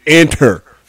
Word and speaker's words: Inter: No, the proper pronunciation, Randy, is Inter.